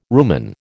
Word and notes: rumen: American English

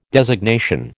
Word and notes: designation: American English

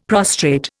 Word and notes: prostrate: Indian

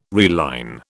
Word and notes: reline: British English

